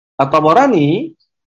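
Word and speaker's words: At-Tabarani (الطبراني): Dia katakan warawa At-Tabarani, Tabarani itu yang tepat, ba-nya di fathah ya. Ini bacanya At-Tabarani, bukan At-Tabarani ya. Karena At-Tabarani ini merupakan nisbah kepada daerah namanya Tabariyah.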